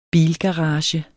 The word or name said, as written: bilgarage